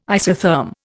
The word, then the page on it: isotherm: Indian